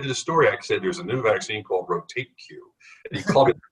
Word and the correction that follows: RotaTeq: six in the morning and said, you're pronouncing it wrong. Change the tape. It's Rotateq.